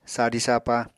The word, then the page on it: sadi-sapa: Apatani